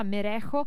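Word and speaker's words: Merezco: che si chiama Io Me Lo Meresco. Ve lo ripropongo, ovviamente ho sbagliato la pronuncia spagnola, Merejo si dice,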